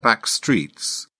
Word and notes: backstreets: British English

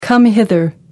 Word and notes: come hither: American English

